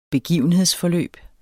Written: begivenhedsforløb

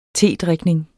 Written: tedrikning

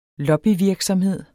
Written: lobbyvirksomhed

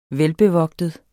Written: velbevogtet

